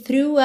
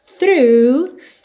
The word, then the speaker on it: through: Through, atenção que esse som é bem fechadinho, o GH do final não é pronunciado, e o começo, f, linguinha lá nos dentes da frente, tá? Through. A life of, esse é o F com som de OV, eu nem precisava falar, porque você já tá craque nisso.